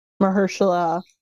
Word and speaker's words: Mahershala: Well, they casted Marcella Ali. How do you say his name? – Oh, okay. Marcella. Yes. Hold on. I know who you're talking about. Mahershala.